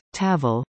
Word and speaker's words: Tafl: Tafl games, pronounced Tavl,